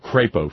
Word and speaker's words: Crapo: I said Crapo, Ferg. No. His name is spelled C-R-A-P-O, Ferg. C-R-A-P-O, Crapo.